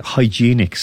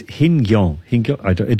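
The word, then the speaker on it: Hienghène: I was going to say hygienic sport. It's not. It's Hingyong.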